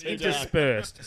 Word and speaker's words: interspersed: South Korea is blasting criticism of the social regime northwards and intersped. Interpursed? – It's not pronounced sped, it's retard. – You only get three of those. – Yeah, it says interspersed, so interwoven with K-pop music.